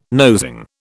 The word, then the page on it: nosing: British English